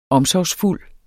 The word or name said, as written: omsorgsfuld